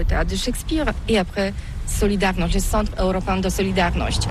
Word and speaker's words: Solidarność: Alors solidarité, en français on dit Solidarnosc, mais ici à Gdansk on ne prononce pas Solidarnosc. – On prononce Solidarność.